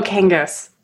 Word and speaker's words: Kangas: Oh, Kangas.